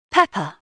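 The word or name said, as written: pepper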